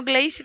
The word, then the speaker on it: Gleice: Muito prazer, me chamo Gleice também. Só que o meu não se escreve Glace, Glace, né? O meu se escreve Glê mesmo.